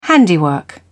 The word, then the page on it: handiwork: British English